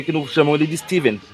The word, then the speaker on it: Stephen: Pronuncia-se Steven. – Stephen? – É, Steven? É, o, o, o, cara que escreveu It, a coisa, por exemplo, é Stephen King.